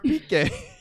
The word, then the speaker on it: Piqué: I'm like, I packed this guy named Pick. His card's blue. And then like, you idiot. – That's Gerard Piqué.